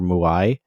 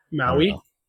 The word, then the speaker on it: Maui: to play uh what how's it pronounced, Maui or Muai? Maui. Maui.